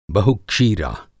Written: बहुक्षीरा

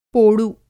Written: पोडु

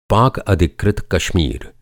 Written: अधिकृत

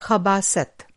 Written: ख़बासत

